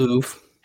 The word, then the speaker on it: Louvre: The Louvre? – It's a Louvre.